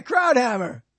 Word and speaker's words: Krauthammer: Yeah, Krauthammer.